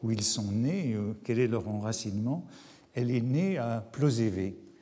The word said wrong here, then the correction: Plozévet: et elle me corrige même, elle me dit qu'il faut dire Plozévette, que le T final se prononce.